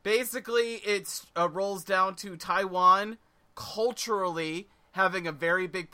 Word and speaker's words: Taiwan: And CJ Ride, an American Twitch streamer who's made a name for himself broadcasting his life in Taiwan. God, I said it wrong. Taiwan.